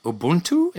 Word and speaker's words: Ubuntu: And speaking of which, shifting gears a little bit, Layla, I saw your TED Talk that you gave in Austria about Ubuntu.